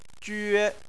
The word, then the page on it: jue1: chinese